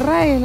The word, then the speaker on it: RAE: En vez de ir, es la RAE, lo dije bien ahora, sí.